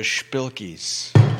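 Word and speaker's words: shpilkes: You could say you've got the spilkies.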